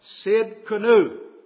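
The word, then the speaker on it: Tsidkenu: Jehovah Sid, spelled T-S-I-D, the T is silent, Sid Kanu.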